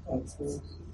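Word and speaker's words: Atzmot (עצמות): How do you say bones in Hebrew? Atzmot. Right.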